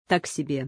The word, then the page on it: Так себе: Russian